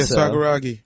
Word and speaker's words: Sakuragi: And, And we think his name is pronounced Sakuragi. Is that sound right? – Yeah, Sakuragi.